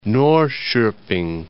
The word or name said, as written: Norrkoping